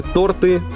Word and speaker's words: торты: Долго ели торты, не налезли шорты.